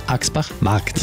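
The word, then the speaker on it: Aschbach Markt: Der Sommertourbus mit Jenny Frank an Bord wird direkt am Marktplatz stehen in Axbach Markt. – Die Betonung liegt auf dem Markt. Es gibt nämlich auch Axbach Dorf auf der anderen Seite der Donau.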